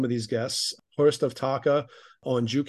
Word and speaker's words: Taake: And then we have, I don't know how you say his name, Hoest of Taka, T-A-A-K-E. – Yeah, I don't know if it's Hurst or... – Hurst. – Yeah. – How do you say his band name? I've never known how to say that. – Taka.